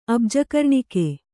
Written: ಅಬ್ಜಕರ್ಣಿಕೆ

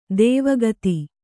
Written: ದೈವಿಕತೆ

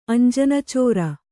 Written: ಅಂಜನಚೋರ

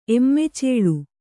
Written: ಎಮ್ಮೆಚೇಳು

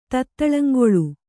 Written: ತತ್ತಳಂಗೊಳು

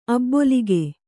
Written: ಅಭಿಲೇಖ

